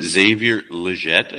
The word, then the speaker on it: Xavier Legette: Real deep, I'm going Xavier Legette, I believe it's pronounced. – Legette, yep.